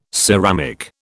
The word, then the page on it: ceramic: British English